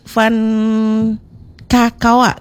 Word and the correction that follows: Van Kakoa: Ini Van Kakoa ya? – Betul. – Bacanya kayak gitu ya? – Iya. – Oke, bukan Van Kakao bukan ya? – Bukan. – Oke, baik, Van Kakoa.